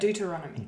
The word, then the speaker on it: Deuteronomy: Deuteronomy.